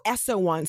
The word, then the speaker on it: Eso Won: Is it S-O-1 or F-O-1? – So Essawan. So it's spelled E-S-O, one word, Wan, W-O-N, Books.